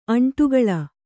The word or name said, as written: ಅಂಡಗೋಲ